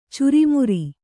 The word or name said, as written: ಚುರುಮರಿ